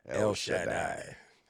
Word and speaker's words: El Shaddai: I just like the way you say it. El Shaddai. – We asked that earlier. Is it... That is, well, anyway, that's how you pronounce it.